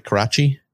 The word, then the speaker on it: Karachi: and I found the stuff that happened in Karachi very interesting. It was kind of the most boring stuff of the show. I thought it dragged on a little long. Did I say that right? Karachi? – Yeah.